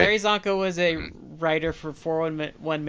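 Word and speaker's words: Csonka: Is it Larry Kasanka? – Larry Zonka. – Larry Zonka. Yeah, Larry Zonka passed away yesterday.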